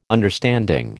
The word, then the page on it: understanding: American English